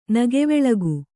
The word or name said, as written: ನಗೆವೆಳಗು